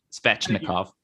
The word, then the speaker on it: Svechnikov: Now, I do want to correct myself. – Svechnikov.